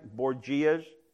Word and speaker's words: Borgias: Anybody know the Borgias from, am I saying it right? Borgias? Borgias, thank you.